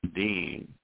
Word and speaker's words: Deen: In the Holy Quran, Allah describes the religion of Al-Islam as Deen, pronounced as though it was spelled D-E-E-N. Deen al-Fitra, Fitra, pronounced as though it was spelled F-I-T-R-A-H, which means the religion established on the laws and principles which uphold the creation.